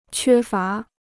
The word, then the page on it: 缺乏: quē fá